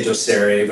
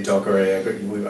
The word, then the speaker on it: Docere: We say Docere, but people say Docere. I've heard everything. – I was thinking it was like Spanish. It's D-O-C-E-R-E, Docere.